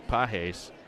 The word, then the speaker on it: Pages: Outfielder Andy Pajes, spelled like pages, but he pronounces it Pajes.